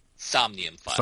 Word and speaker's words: Somnium Files: Somnium Files.